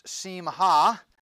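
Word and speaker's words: simcha: In Hebrew, it's pronounced simha, and it means joy, gladness, or mirth as displayed in festivities.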